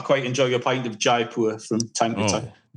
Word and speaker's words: Jaipur: How do you pronounce it, Ryan? – That's what I would have said. – Jaipur. – You got it.